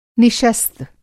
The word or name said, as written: निशस्त